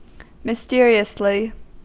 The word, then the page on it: mysteriously: American English